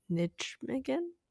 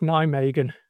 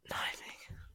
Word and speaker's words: Nijmegen: Will you use adjustable walls like last week in Grip Nijmegen? Sorry, is that terrible? – No, I had to ask a few times. Apparently it's pronounced Nijmegen. – Nijmegen.